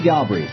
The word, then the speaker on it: Galbraith: Galbraith is with us. Am I saying that right? – Yeah, I have been mispronouncing your and your father's name my whole entire life because I read, you know, I used to read all this stuff, but I didn't hear it.